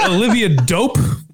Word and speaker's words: Olivia Dope: Is that how you say her name? – Yeah. I think that's just a stage name. It'd be great. – Oh, I get a dope like cool. – Like that's dope.